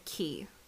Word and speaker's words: Quay: Elizabeth Quay, but it's spelled Quay. So Elizabeth Quay.